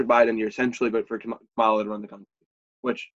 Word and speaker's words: Kamala: Kamala, how do you pronounce her name? – Kamala Harris, yeah.